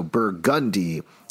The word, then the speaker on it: Burgundy: I mention it because I know Alex, okay, here we go, pronounced it like anyone would normally pronounce Burgundy, but down here we put the emphasis on different syllables. I'm reading this live, so in NOLA, it's pronounced Burgundy with an emphasis on the gun, so Burgundy.